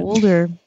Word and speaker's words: Boulder: Boulder.